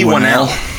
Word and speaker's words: Whannell: Lee Whannell.